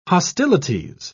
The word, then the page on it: hostilities: British English